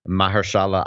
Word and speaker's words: Mahershala: You know, Emma Stone, Aaron Taylor Johnson beat out Mahershala Ali. Is that how you say his name? – Yes.